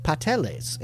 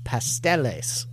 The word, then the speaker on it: pasteles: The dish looks like a tamal, but in Hawaii they are called pateles, in a phonetic rendering of the Puerto Rican pronunciation of pasteles, as discussed below. So you have pasteles, and Hawaii they called pateles. So no S before the T.